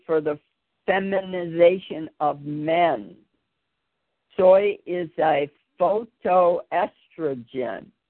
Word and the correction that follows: phytoestrogen: Whoa. – Phytoestrogen. What is it? – A phytoestrogen. – Is that how you say it? Thank you very much. I, I, I yeah, phyto, phyto pertaining to plants.